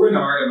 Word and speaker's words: Renard: Today we have Nicole Renard.